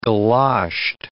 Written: galoshed

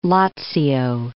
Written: Lazio

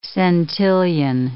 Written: centillion

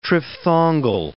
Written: triphthongal

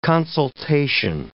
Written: consultation

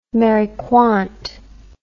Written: Mary Quant

